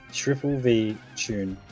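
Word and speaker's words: VVVTune: Triple V tune.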